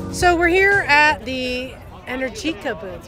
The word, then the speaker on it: Energica: Let me say it with Italian pronunciation. Welcome, everybody. The right name is Energica. – One more time. – Energica. Probably speaking more American-friendly, it sounds more Energica, but yeah, the right pronunciation is, once again, Energica.